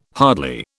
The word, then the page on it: hardly: British English